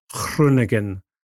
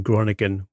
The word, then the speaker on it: Groningen: Certainly, though, it is a great story that he's gone back to his hometown club, and I commend Mr. Castles for his pronunciation of Groningen rather than Groningen, which is the way that most people say that in this country.